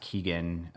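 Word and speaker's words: Keoghan: I think his last name is pronounced Keegan, because I watched this movie called The Killing of a Sacred Deer last night.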